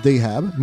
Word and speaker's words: Deheb: And uh, it's pronounced Deheb, means gold in Arab.